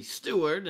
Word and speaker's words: Steward: Well, my real name is actually Stewart, and that's spelled S-T-E-W-A-R-D. Not to be confused with my twin brother, Stewart, S-T-U-A-R-T.